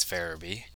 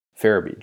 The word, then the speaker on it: Farabee: I think it's Farabee. – Farabee. Joel Farabee.